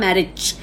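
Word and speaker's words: Marić: Okay, now Mileva or Mileva, it's Mileva, Mileva Maric, and it's Maric, though the spelling is M-A-R-I-C, you pronounce it as C-H, M-A-R-I-C-H, pronunciation is C-H.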